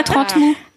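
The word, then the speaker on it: Trentemoult: J'ai Alexis qui me dit que 30 Moult, le bled près de Nantes, il me dit, je sais pas qui t'a dit de prononcer le T, mais ça se prononce pas, j'habite à Nantes depuis 10 ans, 15 ans. – Ah, 30 Moult.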